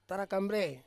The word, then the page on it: tarakambrei: Ansus